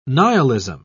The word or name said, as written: nihilism